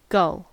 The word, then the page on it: gull: American English